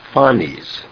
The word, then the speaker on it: Phanes: Then that very big image of the divine child, which he also calls, I checked the pronunciation, it's Phanes, P-H-A-N-E-S, according to my pronunciation dictionary, Phanes, which is another name for Eros.